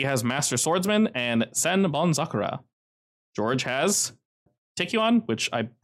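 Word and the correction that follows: Taekkyon: probably yeah, I probably pronounced that wrong, but that's basically his martial art. It's an off branch of Taekwondo, I believe. What is it? Taekyon. It's in chat.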